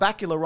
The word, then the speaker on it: Bakula: And Scott Bakula rhymes with Dracula.